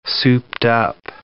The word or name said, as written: souped-up